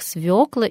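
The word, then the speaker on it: свёкла: Выше мы уже обсуждали правильное ударение в словах «свекла» и «щавель». Я не буду возвращаться, но хочу напомнить, что с тех пор ничего не изменилось. Свекла по-прежнему свекла, и ударение падает на ее, а щавель есть щавель, и долго еще эта норма будет держаться в русском языке.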